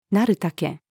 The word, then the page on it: 成る丈: female